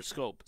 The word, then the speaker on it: Schoop: the Tigers signed a deal with Jonathan Schoop and a 1 year deal, or Scope, sorry, Jonathan Scope, as somebody pointed out. Yeah, somebody actually on Twitter, Lee on Twitter mentioned how to say his name.